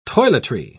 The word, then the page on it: toiletry: British English